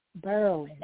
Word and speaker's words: Berwyn: Berwyn.